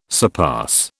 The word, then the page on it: surpass: British English